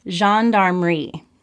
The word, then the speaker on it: Gendarmerie: So Stack tells us that this story was filmed with a complete with complete cooperation of the Belgian Air Force and the Gendarmerie, is how it's pronounced.